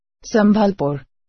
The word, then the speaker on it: Sambalpur: Ili Sigurna sam da smo ovo dobro izgovorila. – Sambalpur. – Ali da pitamo Google Translate. – Tako je. – Kančipuram. Sambalpur.